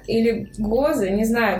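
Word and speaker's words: Gose: Кстати, правильное произношение слова с ударением на букву О. Гозы.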